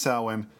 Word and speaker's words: Samhain: People probably heard the word Samhain as mispronounced in the Halloween movies, but that's not how it's pronounced.